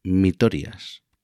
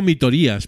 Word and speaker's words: Mitorias: Y en el número cuatro tenemos a Mitorias. Creo que se pronuncia así. Hombre. Mitorias. Sí, yo tengo también dudas. A veces yo digo mitorías, pero debe ser mitorias.